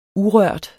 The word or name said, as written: urørt